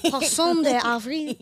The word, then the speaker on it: poisson d'avril: And so they would put paper fish on the back of people, which they call the poisson d'avril, April fish. – Poisson d'avril? – The avril, to symbolize a young and gullible person. – I'm a poisson d'avril. Am I saying that right? Poisson d'avril.